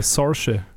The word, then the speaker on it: Saoirse: Äh, Saoirse.